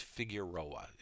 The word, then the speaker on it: Figueroa: Olga Figuera Reyes, Rochester, January 4th, age 85. All services were held privately. I believe her middle name is pronounced Figueroa.